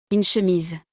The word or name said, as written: Une chemise